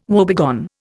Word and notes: woebegone: Indian

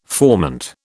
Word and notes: formant: British English

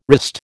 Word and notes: wrist: American English

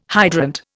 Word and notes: hydrant: Indian

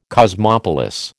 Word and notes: cosmopolis: American English

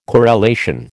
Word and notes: corelation: American English

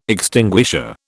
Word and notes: extinguisher: British English

